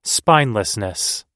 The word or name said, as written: spinelessness